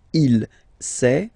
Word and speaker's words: sait: Il sait, final T not pronounced.